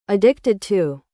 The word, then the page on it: Addicted to: American English